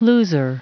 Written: loser

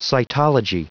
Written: cytology

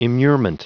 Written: immurement

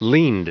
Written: leaned

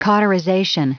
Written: cauterization

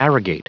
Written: arrogate

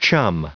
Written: chum

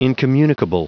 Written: incommunicable

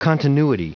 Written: continuity

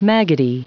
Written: maggoty

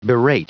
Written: berate

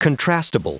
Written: contrastable

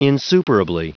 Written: insuperably

